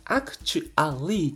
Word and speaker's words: actually: で、この ALLY の発音を、皆さん、僕よく聞くのが、 really とか、 u ー u a l リーとか、 actually っていう発音をしてるんですけど、実際にこの A の発音が消えている